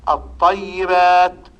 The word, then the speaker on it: الطيبات: وأمثلة ذلك الطيبات، وقعت الطاء بعد لام أل فوجب الإدغام، تقول الطاء، فتنطق بالطاء مشددة، لأننا قلنا سابقا الإدغام يؤدي إلى النطق بالحرف الثاني مشددا.